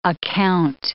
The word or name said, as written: account